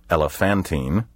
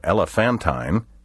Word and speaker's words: elephantine: Elephantine, also pronounced elephantine or elephantine, is spelled like the word elephant plus the letters I-N-E.